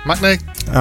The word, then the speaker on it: Maccne: which is Sudden Light by Saverio Maccne. Maccne. I'm confident of that pronunciation.